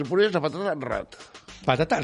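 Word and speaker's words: ratte: Bueno, pero, pero rata, rata. Se, acaba con, una errate, se escribe. – Ah, rate, rate. Y se pronuncia rat. R-A-T-E. – T-T-E.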